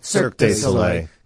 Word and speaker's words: Cirque du Soleil: Cirque du Soleil